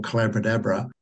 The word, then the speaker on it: Collabradabra: So I began developing what is now what I call Collaboradabra, which rhymes with abracadabra, or the magic of a collaborative conversation.